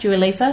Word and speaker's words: Dua Lipa: Yeah, so Kenzie was like, she knew more characters, and she likes, Dua Lipa plays, did I pronounce that right? Dua Lipa? – Yeah.